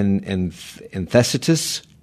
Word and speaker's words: enthesitis: Okay, and enthesitis. How do you say that? – Infectious. – Enthesitis. – Oh, sorry, enthesitis.